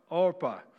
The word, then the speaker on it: Orpah: Orpah, Right. I've said Oprah. I pronounce it wrong. Steve just did the same thing.